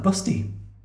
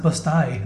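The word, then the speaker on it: Busti: It's actually pronounced Bust-I, apparently. – Bust-I. – That is true. That is I'm from Bust-I. – And they say the reason for that is to differentiate it from other towns called Busty.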